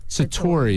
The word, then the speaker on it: Satori: Could you tell the people what Satori is? – Oh, am I pronouncing that right? – Yeah. Say it again. Satori.